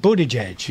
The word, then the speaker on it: Buttigieg: Oh, Mayor Pete Buttigieg.